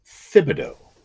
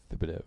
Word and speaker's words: Thibodeau: but it's pronounced Thibodeau. – Thibodeau. – That is what I have heard as the official.